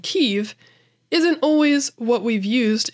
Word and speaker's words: Kyiv: In the Russian Kiev, that very same letter sounds like an F. Neither of those come across in most English speakers' pronunciation of the word, which often rhymes with the words sleeve or give, depending on the speaker.